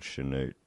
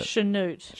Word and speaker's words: Chanute: I need to just mention how good Chanute is as a name. – it's Why hasn't that come up yet? I was waiting for someone to mention it. – Chanute. – Chanute.